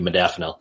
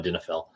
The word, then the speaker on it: Modafinil: But so first, I can never pronounce it right. Modafinil. Thank you. Modafinil. I always want to call it modinafil.